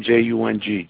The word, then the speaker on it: Jung: J U N G.